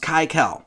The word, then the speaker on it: kaikel: The Yiddish word for circle is kaikel, uh, pronounced kaikel.